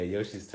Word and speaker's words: Yoshi: Luigi and Yoshi? Is that how you pronounce it? Yoshi? Joshi. Yoshi. I know, I always say it wrong.